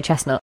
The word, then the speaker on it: chestnut: People don't really pronounce the T very much, though, do they? – No, chestnut. – It's like it's in your head, but you kind of say chestnut. There is a bit of a something there.